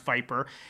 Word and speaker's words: VIPR: I don't know what VIPR stands for, but I do know it's pronounced Viper.